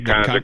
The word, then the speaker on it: Conjure: Conjure, is am I saying that right? – Yeah, Conjure Cognac, which we did a soft launch in Georgia, man, and everybody that, you know, knows about it, they know about it.